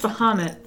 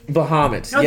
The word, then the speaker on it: Bahamut: I think it's pronounced Bahamut. – Bahamut, yeah. Yeah, that's what I said.